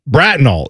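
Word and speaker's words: Bratenahl: Brattonall. – Brattonall is how you say that.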